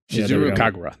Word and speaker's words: Shizuru Kagura: Shizuru. Shizuru Kagura. There we go.